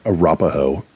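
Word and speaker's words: Arapahoe: 1909, the first SOS distress signal sent by an American ship is sent by the Arafoe off of Cape Hatteras, North Carolina. – It's Arapaho. I know how to pronounce things.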